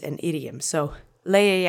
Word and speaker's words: leje: Let's say leje together three times. Accent is on the first syllable. Leje leje, leje.